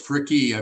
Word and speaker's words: Fricke: Real close, Fricke. I pronounce the E on the end.